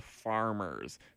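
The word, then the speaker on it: Pfarmers: Yeah, so this first song is by a band called Farmers, but spelled with a P-F, silent P, kind of like pterodactyl, maybe.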